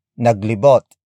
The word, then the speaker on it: naglibot: Finally, we have naglibot. Naglibot. Naglibot. Naglibot is spelled as N-A-G-L-I-B-O-T.